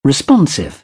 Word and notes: responsive: British English

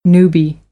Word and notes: newbie: American English

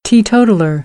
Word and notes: teetotaller: American English; oʊ

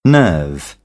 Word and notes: nerve: British English; ɜː

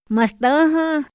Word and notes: ma stäha: Otomí

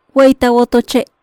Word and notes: Wey ta wotoche': Maya

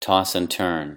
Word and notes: toss and turn: idiom

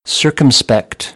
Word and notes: circumspect: American English; ɝː

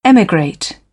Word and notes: emigrate: British English; ɪ

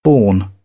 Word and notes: borne: British English; ɔː

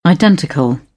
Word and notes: identical: British English

